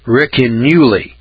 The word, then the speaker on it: Ricinulei: It's called the Ricinulli. It's spelled R-I-C-I-N-U-L-E-I. Ricinulli. That's the way I'm pronouncing it.